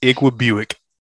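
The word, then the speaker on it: Igwebuike: I knew when I started this guy this week, we always do each other's matchup. And with that guy on there producing negative points, I knew you would have to pronounce his name. So we got to at least try it. – Iqua Buick. – That's pretty good. That's the way the announcers were saying it.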